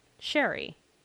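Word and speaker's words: Cherryh: And I apologize in advance, or I post-apologize for mispronouncing C.J. Sherry's name, because apparently it's pronounced Sherry.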